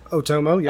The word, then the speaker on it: Otomo: His name is Katsuhiro Otomo. – Otomo, yeah.